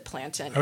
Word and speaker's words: plantain: And I will just say to your readers, which I mispronounced as plantain. – No, you are fine to pronounce it plantain. I'm just going to say to all the people listening that Jamaicans typically pronounce it plantain.